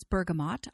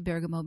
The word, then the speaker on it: bergamot: And the one that I chose particularly from the ester family is bergamot, which many people pronounce bergamot. I say bergamot because that's the way I learned to pronounce it when I was in Europe.